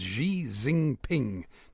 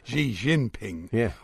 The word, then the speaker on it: Xi Jinping: President Xi Jinping, no, that's not how you pronounce it, Xi Jinping of China, likes a spot of football.